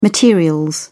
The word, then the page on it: material: British English